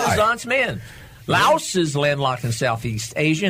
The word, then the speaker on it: Laos: Yeah, and then the other one is Laos. – Really? – Laos. – Okay. – You don't want any Laoses on you. – No, you don't. – L-A-O-S, four letters, one syllable. – And Chad. – And Chad in Africa. – C-H-A-D.